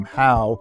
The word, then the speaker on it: Hough: Why do you pronounce your name Howe when it's spelled H-O-U-G-H?